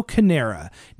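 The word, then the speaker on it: Carnera: Okay, because Primo Canero did I say his name right? No. Primo Canera, nicknamed the Ambling Alp, was a six foot five and a half be- foot beast.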